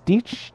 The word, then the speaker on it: dich: It's like dich, D-I-A-C-H is dich, dich.